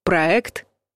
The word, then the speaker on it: проект: Часто в заимствованных словах после гласных буква «е» обозначает звук «э» — «риэлтор» или вот «проект». Такое произношение многим кажется нерусским, но это норма, зафиксированная словарями. Приходится подчиниться и запомнить верный вариант — «проект».